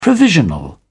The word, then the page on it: provisional: British English